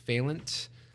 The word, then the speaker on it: Fehlandt: So you can find me on social media, especially on Instagram, main spot, at Oiver Fehlandt.